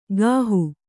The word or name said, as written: ಗಾಹು